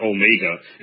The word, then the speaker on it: Omega: That's how you pronounce that. Alpha and Omega. It's not Omega. It's Omega.